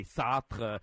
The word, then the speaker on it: Sartre: I happen to become a Sartre fan as well, and you know, I'm from Canada, so they say Sartre. I get the French pronunciation.